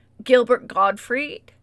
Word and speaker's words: Gilbert Gottfried: Gilbert Gottfried, that's how you say his name, sure.